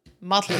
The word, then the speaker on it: Mottle: His name is Mottle. Mottle is how they pronounce it. But Mottle, M-O-T-T-L-E, is like not something that I ever would have.